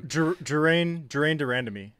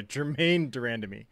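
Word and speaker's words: Germaine de Randamie: I mean, her last fight, I think, with Randamine Dejarmine. I can't pronounce her name. I can't pronounce anybody's name. – Jermaine Durandamy. – Boom. – Jermaine. I'm sorry. Jermaine Durandamy.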